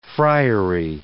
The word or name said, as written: friary